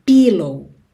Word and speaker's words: pillow: Se escreve com I, mas não é esse I, pillow. Pillow, tá? É pillow, pillow.